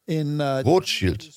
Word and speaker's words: Rothschild: Rothschild, wie es auf Deutsch ausgesprochen wird. Das rote Schild.